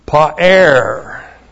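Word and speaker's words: Pa'er (פָּאַר): One, it comes from the verb pa'er.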